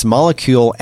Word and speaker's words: Molekule: You can go to, it's spelled, it has a fun spelling. It's molecule MOLEKULE.